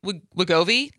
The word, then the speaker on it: Wegovy: and Wegovy. Is that... Did I pronounce it right? Mm-hmm.